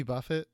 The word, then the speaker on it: Buffett: Does your dad like Jimmy Buffett? – Future Keon, please. – I don't think my dad likes Jimmy Buffett. My dad is more of like a... – It's pronounced buffet.